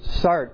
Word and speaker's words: Sartre: Sartre.